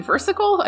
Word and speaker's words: versicle: Is it versicle? I feel like I'm saying that wrong. – Yeah, versicle. I mean, it sounds like it means little verse, which I guess. – Right. I just thought of like a fudgesicle as all.